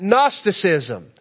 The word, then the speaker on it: Gnosticism: There's a silent G in that, Gnosticism.